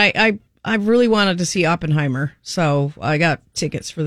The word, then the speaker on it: Oppenheimer: Now, I said Oppenheimer, but you said Oppenheimer, which I'm sure is the correct way to say it. Oppen, because it has two P's, and so yeah.